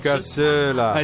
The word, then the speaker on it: cassola: È impronunciabile la casula, la, la casula, la cassola. non so come si pronuncia. La cassola. Esatto, sì, bravo, bravo quella.